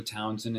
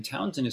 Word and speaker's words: Townshend: So Joshua Townsend, and Townsend is spelled Old English with an H, so S-H-E-N-D.